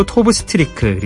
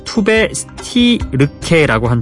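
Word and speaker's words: Tove Styrke: 영어식으로 토브 스트리크 그리고 스웨덴 발음으로 투베 스티르케라고 한다는 스웨덴 출신 신인 가수의 매력 있는 노래였습니다.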